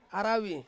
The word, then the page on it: aravi: Ansus